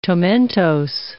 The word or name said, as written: tomentose